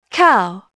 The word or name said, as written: cow